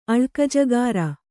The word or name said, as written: ಅಳ್ಕಜಕಾಱ